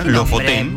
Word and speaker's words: Lofoten: Se escribe como suena. Lofoten.